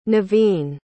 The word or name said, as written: naveen